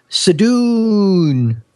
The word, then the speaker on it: Sadoon: I think she put a lot more vowels in there than you always. It would be like Khan, right? It'd be like Sadoon.